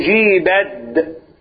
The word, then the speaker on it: أجيبت: نقول أجيبت التاء لا تنطق التاء لا تنطق هنا شكرا.